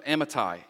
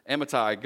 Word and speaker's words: Amittai: The word of the Lord came to Jonah, son of Amittai, and that is how you say it. That's just not my hillbilly slang. That is how you pronounce it. I actually looked it up. Amittai, go to the great city of Nineveh and preach against it, because its wickedness has come up before me.